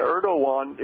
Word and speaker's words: Erdogan: President Erdogan, I believe is how you pronounce it. You know, Erdogan.